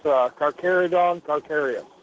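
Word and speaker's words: Carcharodon carcharias: Yeah, I know I'm mispronouncing this, Carcharodon, Carcharonis, or something like that. Was that the megalodon that you were referring to? – Yeah, if I remember correctly, he got real big into sharks after this. It's Carcharodon, Carcharias. – Right. That's probably better pronounced.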